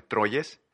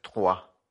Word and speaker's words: Troyes: Eh, mucha gente lo conoce como el Troyes, eh, porque así se escribe, pero pues es francés, Trois.